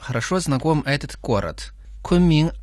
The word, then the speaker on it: 昆明: Кун произносится в первом тоне, а Мин читается во втором тоне. Повторяйте за мной. Кун Мин.